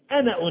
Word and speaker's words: أنا: ف وقال الذي نجا منهما وادكر بعد أمة أنا أنبئه، أنا طبعا عندنا كلمة قف الألف بتاعت أنا لا تنطق، لو كنا ورش كانت تنطق وتطيب كمان، يعني ورش كنا نقول أنا أنبئه،